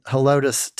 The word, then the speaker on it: Helotes: In Helotes, Texas.